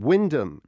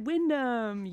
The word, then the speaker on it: Wymondham: Wyndham is how it's pronounced. – Ding, ding, ding, ding, ding. Correct. Wyndham.